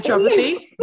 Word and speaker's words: naturopathy: that natural how do you say it naturopathy naturopathy naturopathy there you go um, i've yeah.